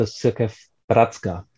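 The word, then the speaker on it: Církev bratrská: Um, please provide a short overview about the Cirkev Bratrska. Did I pronounce it right? Yes, yes.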